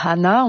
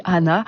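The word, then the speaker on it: għana: Alors ce film met en scène un chanteur de Hana. On écrit Ghana, pour prononcer à la française, mais on dit Hana.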